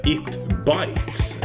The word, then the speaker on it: IT Bytes: och välkomna till denna nya podcast som heter It Bites, eller IT Bites. Det beror lite på hur man uttalar det och hur man vill formulera det.